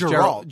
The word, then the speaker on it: Geralt: Is it a soft G or is it a hard G? – I think it's Geralt. Geralt.